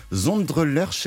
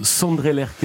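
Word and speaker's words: Sondre Lerche: Zondre Lerch, est-ce que je le prononce bien ? – Sandre Lerche, normalement.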